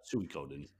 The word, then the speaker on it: Suikoden: Oh, I'm not even sure I said it right, but I think you did. Yeah, Suikoden.